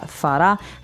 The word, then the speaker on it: farà: So farà is in the future. We have fare, farà. So the accent is on the last syllable of that, farà.